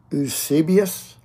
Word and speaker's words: Eusebius: Let me spell it first E U S E B I U S. Eusebius, Eusebius of Caesarea.